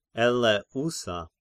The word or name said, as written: Eleousa